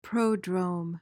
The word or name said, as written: prodrome